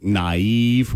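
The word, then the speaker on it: naïve: It's most common in, like, naive, where the I should be sounded separated from the A.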